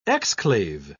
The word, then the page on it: exclave: British English